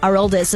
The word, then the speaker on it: Aroldis: Boston pitcher Aroldis, I know it's Aroldis is how they say it. I think Aroldis is how he would say it.